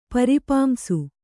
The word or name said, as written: ಪರಿಪಾಂಸು